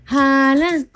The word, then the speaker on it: Haaland: Haaland. – Haaland.